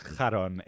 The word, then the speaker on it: Charon: So one time, for example, he was eyeing his buddy's boyfriend at a party, and his buddy Karen, it's of course pronounced Charon in Greek, but we'll call him Karen.